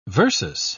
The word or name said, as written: versus